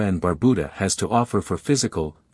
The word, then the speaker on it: Barbuda: About Antigua and Barbuda. Antigua, pronounced Antigua, and Barbuda, Barbuda, is located in the heart of the Caribbean Sea.